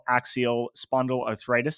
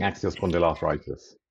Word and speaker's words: axial spondyloarthritis: including serving as the chairman of the National Axial Spondyloarthritis Society and as an executive committee member of their international federation.